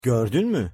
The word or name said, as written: gordun mu